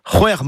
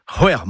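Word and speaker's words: c'hwec'h: Hwer, c'est six, c'est apostrophe H au début et à la fin.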